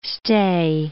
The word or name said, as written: stay